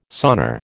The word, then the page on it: sonar: American English